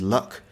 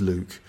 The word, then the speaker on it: Luke: Lots of learners of English think that my name is Luck. In fact, my name is Luke. It's all right. It's something that it's very easy to mispronounce, but it's Luke.